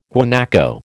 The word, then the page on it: guanaco: American English